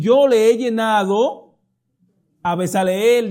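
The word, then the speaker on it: Bezaleel: Estoy hablando de Besaleel o Besaleelí, como se dice. Muchos pronuncian la es ahí, en el hebreo casi no se pronuncia. Besaleel podremos decir.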